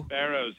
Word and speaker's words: Barros: Is it Barros, Barros, may I borrow? – Barros, you got it.